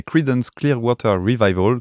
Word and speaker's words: Creedence Clearwater Revival: Bon, et eh ben on va attaquer Oui on va commencer tout de suite avec une chanson très connue des Creedence Clearwater Revival.